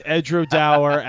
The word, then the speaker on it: Edradour: The tour guide said it's Edradour as in flower.